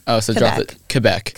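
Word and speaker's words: Québec: Well, a lot of people say Quebec, but I say, I mean, in French, it's Quebec.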